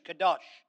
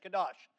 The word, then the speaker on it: qadowsh: Strong's reference number 6918 for Old Testament words is spelled Q-A-D-O-W-S-H, Kadosh. It interprets Jehovah as the Holy One 50 times in the Old Testament. All right? Q-A-D-O-W-S-H, Kadosh.